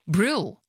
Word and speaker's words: Brule: But they call it Brule. – Brule. Brule Lakes. – Yep. – And so do you think, do you go along with the idea, that you should say it the way the locals say it? – Nah. – No. – I say it the way it's written.